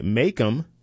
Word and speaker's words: MACOM: On January 14, Makem, that's spelled M-A-C-O-M, which bought out Wolfspeed's radio frequency division along with its RTP fabrication facility in 2023, joined the list. Makem